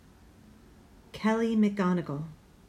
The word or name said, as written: Kelly McGonigal